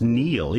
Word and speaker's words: Niall: I think you actually say it's pronounced Neil, even though it's spelt Niall. – Okay. – Because he's Irish.